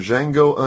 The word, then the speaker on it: Django: Next up, we've got the new Quentin Tarantino film, Django Unchained. – The D is silent.